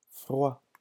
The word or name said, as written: froid